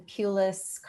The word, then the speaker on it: Pulis: So if you search on my name, it's Robin Pulis Carney, and Pulis is spelled P-U-L-I-S. Carney is C-A-R-N-E-Y.